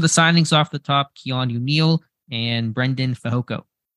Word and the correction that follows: Braden: Is it Brendan or Braden? – Braden. I think I'm saying that incorrectly. Braden. Oh, okay. It's spelled a little bit weird there.